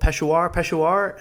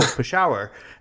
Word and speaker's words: Peshawar: And I remember someone running into the newsroom and saying, how do you pronounce this word? This city in Pakistan called Peshawar? And I'm like, no, Peshawar.